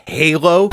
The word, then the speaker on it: Halo: Actually pronounced, it's pronounced Halo.